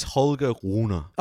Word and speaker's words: Holger Rune: Is a little offbeat, a man who hails from your neck of the woods, Holger Rune. Can you give me the pronunciation, please? – Yes, it is Holger Rune.